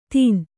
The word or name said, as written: ದಿನ